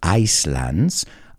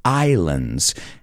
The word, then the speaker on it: islands: How many islands, y cuidado con la pronunciación, no es islands, islands.